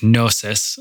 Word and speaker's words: Knosis: He's still putting out new music under his new project, Knosis. That's K N O S I S. I believe that's how you pronounce it as well.